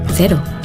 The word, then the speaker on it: cero: In Yucatan, the local Mexican surfers pronounce cero, cero. During the warm summer evenings in bars around the coast of Nayarit, you'll hear them say cero. In New Zealand, zero alcohol Corona cero is pronounced cero.